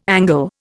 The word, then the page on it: Angle: Indian